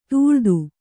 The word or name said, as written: ತಳೆತ